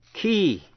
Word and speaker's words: quay: Key, đánh vần Q-U-A-Y là bến tàu, và key là chìa khóa hay là lời giải đáp, K-E-Y. Hai chữ phát âm như nhau.